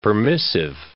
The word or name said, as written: permissive